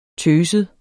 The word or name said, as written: tøset